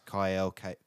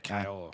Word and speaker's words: Kael: Yeah, and then they got Chris Kyle, I think is how you say it. K-A-E-L. – Yeah, Kyle.